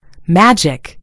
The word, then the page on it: magic: American English